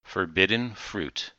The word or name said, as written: forbidden fruit